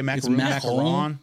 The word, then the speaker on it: macaron: Is that how you say it? – Macaron? That's how you say it. – What? – Macaron? – Okay. – That's how you say it in French.